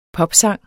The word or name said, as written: popsang